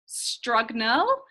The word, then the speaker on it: Strugnell: Okay, my name is spelt Renee, R-E-N-A-E, Strugnell, S-T-R-U-G-N-E-L-L, Strugnell.